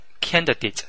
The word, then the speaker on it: candidate: 选举当中的候选人英文也叫做 candidate, 表示候选人或者求职者的时候 candidate 后面那个 A 的发音啊，可以发成短音，读成 candidate。